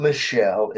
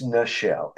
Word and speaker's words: Nichelle: It's not Michelle, it's Nichelle, Nichelle.